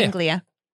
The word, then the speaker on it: Anglia: Anglia.